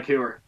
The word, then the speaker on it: Heuer: I don't think Tag Heuer is how you pronounce it. – Is it Tag Heuer or Tag Heuer? – It's Heuer, man.